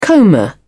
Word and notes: comber: British English

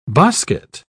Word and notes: bosquet: British English

